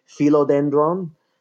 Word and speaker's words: philodendron: It actually comes from Greek, so it would be something closer to philodendron. All of the vowels, you say them, and usually if you pronounce all the vowels as long vowels, you're pretty close to the original Greek or Latin.